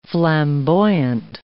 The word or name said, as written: flamboyant